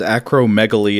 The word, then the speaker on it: acromegaly: Today it's known as acromegaly, and I'm not positive, but that's the way I've always heard it pronounced.